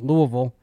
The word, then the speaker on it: Louisville: North Carolina 9.5-point favorites tonight against Louisville. I pronounced it correctly, by the way.